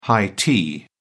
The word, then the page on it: high tea: British English